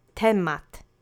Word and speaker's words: temat: The next word is temat. It means topic. This is also a noun in its singular subject form. Let's say temat together three times. The accent is on the first syllable.